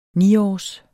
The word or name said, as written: niårs